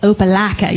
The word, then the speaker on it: Opelika: And I was born in a tiny little town called Opelika, Alabama. And if you don't say it like Opelika, you're not saying it right.